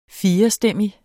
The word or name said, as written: firestemmig